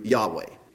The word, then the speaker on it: Yahweh: And the letters of that pronounced in Hebrew, Yahweh.